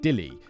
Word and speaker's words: Delhi: The Hindi word for loose is deal, which sounds like Dilly, which is how many people pronounce the city's name.